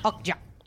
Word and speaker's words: Okja: And how do you pronounce it? – Okja.